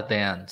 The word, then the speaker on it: Them: Ah, eu assisti uma na Amazon Prime que se chama Them. Corrija minha pronúncia se estiver errada, Logan. Mas é eles, tipo, eles. – Ah, Them, sim.